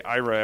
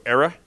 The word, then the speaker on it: IRA: And by the way, most people struggle to say IRA. Everyone wants to go ERA. – Area. – And I always tease, anyone from Boston, like that's the Boston pronunciation of IRA is ERA.